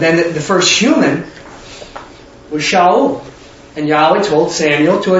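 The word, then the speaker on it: Shaul (שאול): But what we're going to look at is David, and actually how you pronounce his name is Dawid, like W, or D-A-W-E-A-T-H, Dawid. And Saul is Shaul, like S-H-A-U-A-L, or something like that. I don't know how you, phonetically how you spell it. But it'd be Dawid and Shaul.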